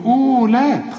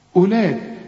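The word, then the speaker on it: أولات: شوف أولاد، اوعى تقول لي أولاد، خطأ، أولاد كأن الواو غير موجودة، تكتب ولا تنطق.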